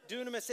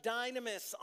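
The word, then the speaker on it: dunamis (δύναμις): Dunamis. It looks like dynamis on the screen, but it's actually dunamis in the Greek is how you say it.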